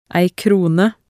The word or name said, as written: ei krone